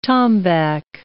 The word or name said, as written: tombac